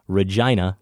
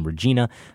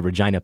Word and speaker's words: Regina: Regina, Saskatchewan. The name of that city isn't pronounced like the woman's name, Regina. The I is pronounced I, not E. A helpful pronunciation guide. It rhymes with vagina.